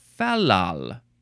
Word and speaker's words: fal-lal: Next word is falal or falal. Falal. You could emphasize either syllable. It's fine. F-A-L-L-A-L, but there is a hyphen between the middle L's.